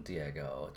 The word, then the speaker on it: Diego: Yeah, so I'm Diego, just like Dora's cousin, I guess, because that's like an easy way to introduce myself.